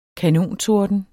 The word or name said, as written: kanontorden